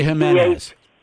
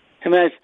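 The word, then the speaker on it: Jiménez: And this guy, Hernandez, or whatever his name is, how do you say his name? The J? – Eloy Jimenez. – Jimenez.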